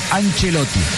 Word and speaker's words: Ancelotti: Y dice, la CEP se pronuncia como Che. Entonces, Ancelotti.